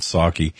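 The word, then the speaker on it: Psaki: Jen P is silent. Saki.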